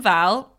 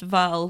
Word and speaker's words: Wall: Kim Val, it's spelt Wall, but it was pronounced Val.